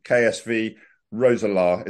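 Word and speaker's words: Roeselare: And in September 2018, you left to become chief executive at Belgian club, and forgive my pronunciation, KSV Roeselaar. Is that correct? – Correct.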